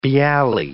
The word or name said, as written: bialy